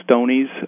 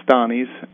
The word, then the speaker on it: Stonie's: A lot of people mispronounce it as Stoney's because it is S-T-O-N, but it's always been pronounced Stani's.